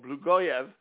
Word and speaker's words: Blagoev: That was from Johns Hopkins, okay, Dr. Juliana Pare-Blogoev, okay, and also, actually I pronounced that wrong, Blagoev,